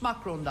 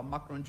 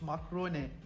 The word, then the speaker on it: Macron: Burada 42 saniye elini bırakamamış galiba Macron Joe Biden'ın. Yalnız Biden Macron'un ismini yanlış telaffuz etmiş. Macrone diye telaffuz etmiş gördüğüm kadarıyla.